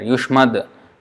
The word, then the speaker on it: yushmad: But if you see in the verse, that ends with a D there, Yushmad, because next word is Angraya. Yushmad Angrayaha, instead of saying Yushmat Angrayaha, it is difficult to pronounce. Yushmad Angrayaha is easy to pronounce, the transition is easy.